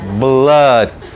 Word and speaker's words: blood: Blood blood. Como bien lo señala eh, la fonética que está ahí, es una A, pero es una A medio rara. Blood, blood. Una A, pero la vamos cerrando. Blood.